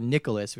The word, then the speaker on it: Nicalis: So MacMillan went to the publisher Nicalis, which is N-I-C-A-L-I-S. Apparently that's how it's pronounced. I've been saying it Nicalis or something.